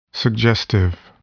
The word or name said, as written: suggestive